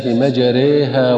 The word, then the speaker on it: مجريها: لاحظ في أثناء القراءة النطق الصحيح لكلمة مجريها، فهي الكلمة الوحيدة التي تمل لحفص، تنطق هكذا: بسم الله مجريها ومرساها.